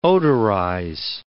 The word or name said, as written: odorize